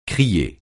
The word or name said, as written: crier